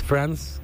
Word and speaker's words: Franz: Bueno, Franz, supongo que se pronuncia. Es Franz, como Francia en inglés, pero sin la A.